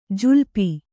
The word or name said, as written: ಜುಲ್ಪಿ